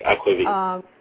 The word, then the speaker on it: Aquavit: You became a sous chef at Aquavit. Am I pronouncing that correctly? – Yes, that is correct. Aquavit.